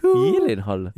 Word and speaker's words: Gyllenhaal: The Americans pronounce it Gyllenhaal, but it's Swedish, and it's actually Gyllenhaal. – Yolehihu. – Gyllenhaal.